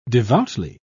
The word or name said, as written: devoutly